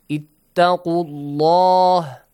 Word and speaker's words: ittaqullah: If we have a fatha or a dhamma sound before the word Allah, the letter lam must be pronounced heavily, like ittaqullah with full mouth. Ittaqullah.